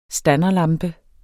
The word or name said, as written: standerlampe